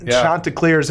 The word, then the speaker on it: Chanticleers: So congratulations to the Chanticleers. – That's right, Chanticleers. – Make sure you say that right, Chanticleers.